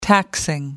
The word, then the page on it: taxing: American English